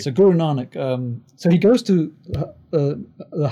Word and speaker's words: Guru Nanak: But Guru Nanak. – Guru Nanak.